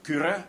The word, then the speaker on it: Kyrö: I've just finished a novel called The Beggar and the Hare by a Finnish novelist called Tuomas Kyrö, which is spelled K-Y-R-O, Kyrö.